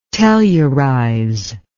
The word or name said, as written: tellurize